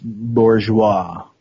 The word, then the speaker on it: bourgeois: To our intended abolition of bourgeoisie's property, the standard of your... – It's pronounced bourgeois.